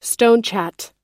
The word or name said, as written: stonechat